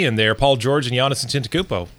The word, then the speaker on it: Antetokounmpo: And there's another dude who also is a favorite for MVP and Defensive Player of the Year, one Giannis Antetokounmpo.